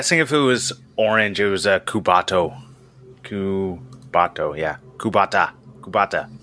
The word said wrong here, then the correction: Kubota: Anyways, I don't think that's how it's pronounced. I think it's Kubo- Kubota.